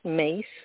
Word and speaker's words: Myss: And she spells her last name M-Y-S-S, but it's pronounced Mace, like the spice.